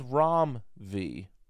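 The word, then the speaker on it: Ram V: The proper way to pronounce this person's name is Rom V.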